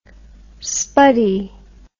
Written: spuddy

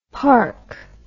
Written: parc

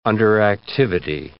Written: underactivity

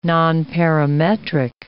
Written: nonparametric

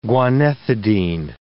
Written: guanethidine

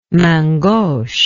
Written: main gauche

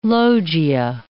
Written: loggia

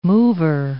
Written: mover

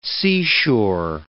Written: seashore

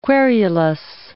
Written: querulous

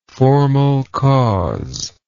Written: formal cause

